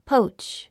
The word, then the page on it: poach: póutʃ; ポウチ